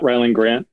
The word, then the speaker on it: Rylend Grant: Yeah, I am at Rylend Grant on social media. That's R-Y-L-E-N-D-G-R-A-N-T.